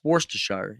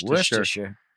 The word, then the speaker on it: Worcestershire: And you're like, no, it's Worcestershire. You go, oh, yeah. – I'd love to meet the guy, though, just to get the correct pronunciation. – Worcestershire.